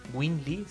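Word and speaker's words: Nguyên Lê: Si pueden por ahí checar el disco Homescape del Win Le. Se escribe Nguyen. N, la letra N, Nguyen Le.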